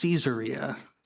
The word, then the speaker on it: Caesarea: in a city called Caesarea." Now, might be better pronounced Caesarea, city named after the first Roman emperor, Caesar Augustus.